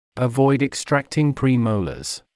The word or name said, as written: avoid extracting premolars